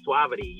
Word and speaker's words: Suavity: So, talk to me about the creation of Suavity. Am I saying that right? – One. You are saying it wonderfully. Yes, it is Suavity. You got it.